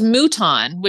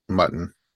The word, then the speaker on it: Mouton: It was Mouton, which is French for sheep, but nobody knew how to pronounce it. And I was okay to say goodbye. – Mutton. – Mutton, mountain, you know, a shocking variety.